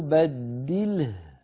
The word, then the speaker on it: بدله: ثم قلنا لما قرأنا أو بدله، فلما وقفنا عليها الهاء في بدله سكنت من أجل الوقف، وقبلها لام ساكنة أصالة فاجتمع في النطق ساكنان، اللام ساكنة أصالة والهاء ساكنة عروضا بسبب الوقف، فهذا يحتاج إلى شيء من الانتباه حتى لا يتحرك شيء من السواكن،